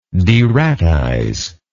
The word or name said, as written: deratize